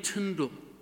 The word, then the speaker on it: Tyndale: It's spelt Tyndale, just as an aside. It's spelt D-A-L-E, but it's actually pronounced in the same way that spindle. It's William Tyndale.